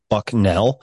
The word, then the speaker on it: Bucknell: Now, in the beginning of this video, he's going to name a college and he mispronounces it. It's actually called Bucknell. And this is in a town neighboring mine. So is it called Bucknell? – Bucknell is what how he pronounces it, but it's Bucknell.